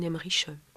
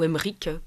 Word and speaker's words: Emmerich: Et en fait, ça représente bien de façon, picturale finalement, par l'iconographie en tout cas, ce que ces visions d'Anne-Catherine Emmerich... ou Emmerich, pour le prononcer à la germanique, eh bien, traduisent.